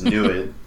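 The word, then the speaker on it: NEID: Yeah, and these are the Habitable Planet Finder and the NEED instrument, right? – Right. I'm forced to correct you on this. The pronunciation is NUID.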